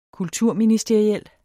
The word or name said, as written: kulturministeriel